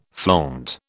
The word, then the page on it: flaunt: British English